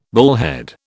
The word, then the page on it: bullhead: British English